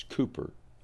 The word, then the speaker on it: Cowper: It's spelled Cowper, but it's Cooper, William Cowper.